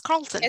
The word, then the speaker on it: Carlton: I think I was adding an extra letter. It's Carlton. It's Carlton.